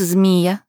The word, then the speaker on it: Змия: «Меня зовут Змия, с ударением на «и», — ответила блондинка с интересом, взглянув на доблестного лыцаря.